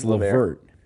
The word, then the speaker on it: LeVert: Is it Caris LeVert? – It's LeVert.